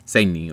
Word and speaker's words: Segni: Segni, or... – Yes. – Yeah, the soft G.